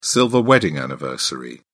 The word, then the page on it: silver wedding anniversary: British English